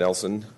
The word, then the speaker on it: Nelson: My name is Chad Nelson. Last name is spelled N-E-L-S-O-N.